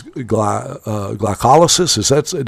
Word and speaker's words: glycolysis: It's in every one of the metabolic processes in the body, such as glycolysis. Did I pronounce that right? – Hey, you pronounced it perfectly.